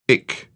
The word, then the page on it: ic: British English